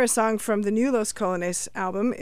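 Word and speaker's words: Los Colognes: They are called Los Colones, even though it's spelled like the colognes that you put on yourself when you're going out for a special date.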